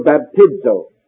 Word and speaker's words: baptizo: This word baptizo. The Z in the Greek is pronounced like ZD.